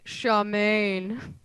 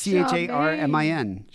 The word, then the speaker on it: Charmin: Can I just point out the differences in how you say it? Because over here we call it Charmaine. – Charmaine, you say that? It's Charmaine. – That's how it's pronounced. You'd be like, oh, get the Charmaine. – But there's not. It's not even spelled. That's not even spelled phonetically. It's C H A R M I N. Charmin. – Charmaine. That's how you say it over here.